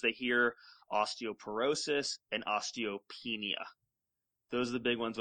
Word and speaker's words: osteopenia: So osteopenia.